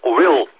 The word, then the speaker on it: Will: My name is Will, and I will spell it for you, Whiskey India Lima Lima.